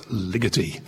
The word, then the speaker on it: Ligeti: In Hungarian, the accent always falls emphatically on the first syllable of the word, so it's Ligeti.